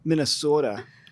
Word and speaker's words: Minnesota: Now, how do you say Minnesota? Like, I don't think we're saying it right. – No, we're not. – You're not. Minnesota. – Okay. – You gotta elongate that.